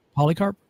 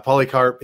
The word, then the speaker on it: Polycarp: I mean, you have I'm going to mispronounce his name now. Polycarthy. He was an understudy of Polycarp. – A Polycarp, that's what I meant. Yeah, Polycarp. He was an understudy of,